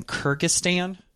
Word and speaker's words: Kyrgyzstan: I just looked up the letter M, and it's in, I think, I'll see now I'm going to butcher this one. I think it's in Kyrgyzstan. – Oh, yeah. – It's one of the stands. – You nailed it.